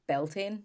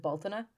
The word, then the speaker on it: Beltane: it but like we would pronounce it Baltana. – Okay. – It's Baltaine. – Okay. – So it looks like Beltane if you were to read it.